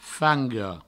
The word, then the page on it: fanga: Provençal; fango